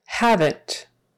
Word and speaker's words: haven't: Haven't, haven't. So that is a contraction that does have two syllables when we pronounce it. Haven't, short for have not. Haven't, haven't. And a V in the middle there.